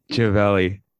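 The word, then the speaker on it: Živjeli: Gevelli. That's it. – You want to spell it for everybody and especially me? – Yep. So it's Z with the little tick over it. IVJELI.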